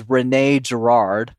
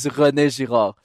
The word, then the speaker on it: René Girard: So the English, I guess, pronounce it, which is wrong, is René Girard.